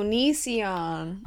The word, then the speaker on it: Onision: How do you say his name? – Oh, Onision. – Onision? Oh, my God. That's... – Onision. Onion son.